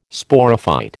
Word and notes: Sporophyte: American English

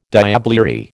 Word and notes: diablerie: American English

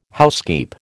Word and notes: housekeep: American English

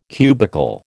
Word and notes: cubical: American English

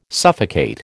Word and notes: suffocate: American English

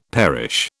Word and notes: perish: British English

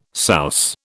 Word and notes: souse: British English

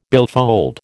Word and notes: billfold: American English

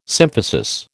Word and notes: symphysis: American English

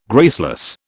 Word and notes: graceless: British English

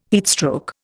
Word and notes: heatstroke: Indian

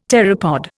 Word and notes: pteropod: Indian

